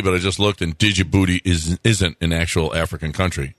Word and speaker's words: Djibouti: It is spelled where you could pronounce it Djibouti. – Yeah. – But it's just Djibouti. I think the D is silent.